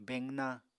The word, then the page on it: beqna: Apatani